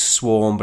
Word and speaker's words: SWVRM: Dead Poets Society, we are DPS on Twitter, and the song is Swarm, but it's spelled SWVRM.